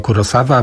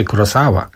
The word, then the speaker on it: Kurosawa: To Kei Ishikawa, Ishikawa się właściwie mówi, no tak, ciągle ten problem, bo to tak zawsze z Kurosawą było, że się całe lata w Polsce mówiło Kurosawa, a właściwie Kurosawa powinno być.